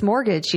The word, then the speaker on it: mortgage: It's mortgage. You know, the T is silent. M-O-R-T-G-A-G-E.